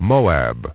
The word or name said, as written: Moab